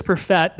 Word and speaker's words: Perfett: Find me on Twitter, at Chris Perfett, that is spelled P-E-R-F-E-T-T.